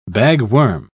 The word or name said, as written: bagworm